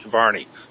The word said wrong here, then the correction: Varley: How do you say his name, Brett? – Her? – Her. Yeah, please. – I believe it's Varley, isn't it? – Varley, yeah. So how much of this,